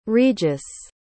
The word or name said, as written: regis